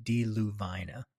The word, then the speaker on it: Deluvina: That's the way I've determined I'm going to pronounce it, Deluvina. In my first film, Billy the Kid in the Lincoln County War, I used Deluvina, but I'm changing it.